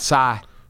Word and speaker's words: Psy: We were talking about Psy. – That's how you say it. – Yeah. – Spells it P-S-Y.